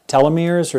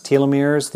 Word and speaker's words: telomeres: But anyway, and nonetheless, the people at the end of their 3-month retreat, they had preserved telomeres, or sometimes pronounced telomeres,